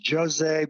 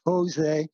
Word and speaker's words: José: So particularly José Dubé, he's from Brazil, so it's pronounced José, but if you speak Spanish instead of Portuguese, it's José. But José Dubé, he has a lot of stuff that he posts on Facebook, which is great.